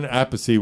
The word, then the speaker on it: Appice: Carmen Appice was here, or Peace, however you want to pronounce it.